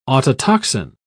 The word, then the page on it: autotoxin: British English